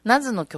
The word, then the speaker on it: NAZ: ナズの曲です。 NAZ と書いてナズ。で、 Save My Life。はい、失礼しました。聞いてもらいましたのは NAZ と書いてナズね。ナズの新しい配信で聴ける音源になってます。 Save My Life。 Life じゃないよ。私の嘘を守っての方の Life ですね。はい。で、ナズ